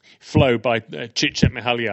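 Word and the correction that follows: Csikszentmihalyi: and I hope that I'm pronouncing that. – I believe it's Cheikh Zen Mahai.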